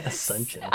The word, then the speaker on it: Asunción: I probably did not say that right. God. – Asunción. – I did not say that right, but it's Asuncion.